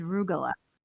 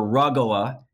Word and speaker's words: rugelach: See, it has to sound like you have a severe sinus infection or something to say it right. Rugelach or rugola,